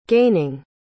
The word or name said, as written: gaining